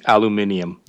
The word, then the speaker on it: aluminium: It's aluminum, so it's not overly heavy at all. – Brad, yeah. – I think you mean aluminium. – Yeah, thank you. Say it right.